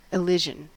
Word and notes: elision: American English

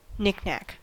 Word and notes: knickknack: American English

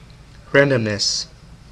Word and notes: randomness: American English

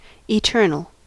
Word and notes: eternal: American English

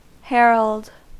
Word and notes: herald: American English

